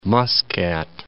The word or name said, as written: Muscat